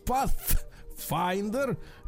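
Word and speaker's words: Pathfinder: Nissan Pass, Нет, надо говорить так. Pass. Finder.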